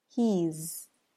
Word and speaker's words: he's: Now the apostrophe S for the word is in these three contractions is pronounced more like a Z sound for he and she, and as an S sound for it's.